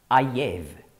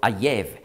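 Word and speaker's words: Ayev (אויב): Übrigens, Hiob spricht man hebräisch aus Iyov und Feind Ayev. Das ist im Hebräischen ganz ähnlich geschrieben. Also Iyov, Ayev.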